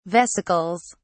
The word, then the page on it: vesicles: British English